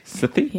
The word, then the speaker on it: Sethi: I don't know if I'm pronouncing that right. Sethi? – Yeah.